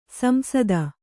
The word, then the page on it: ಸಂಸದ: samsada